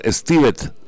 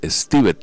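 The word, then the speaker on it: Stiveth: En primer lugar, Stivet, el nombre de él se escribe con TH al final, Stivet, pero usa más el Javier.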